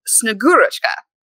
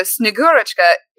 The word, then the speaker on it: Snegurochka: There's a figure called Snegurochka. And I think I said that right. Yuli said that the emphasis goes on the second syllable. So Snegurochka is typically depicted as a beautiful woman with pale skin.